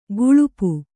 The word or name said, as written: ಗಳಪ